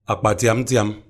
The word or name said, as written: Akpatiangtiang